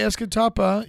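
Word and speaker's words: Escatawpa: It is. It is actually Escatapa. Yeah, it's an unincorporated community and census designated place. – Cool. – Nice. – Give us more info on Escatapa, Mississippi.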